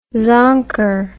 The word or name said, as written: zonker